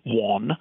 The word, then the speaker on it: Whann: My name is Sandy Wann, that's spelled W-H-A-N-N, and I'm the president of Leidenheimer Baking Company in New Orleans.